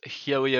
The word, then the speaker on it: Hughie: Okay, so my name is Hughie O'Grady. It's like Huey with an O. My parents are weird. They'd like the name. So Hughie it is.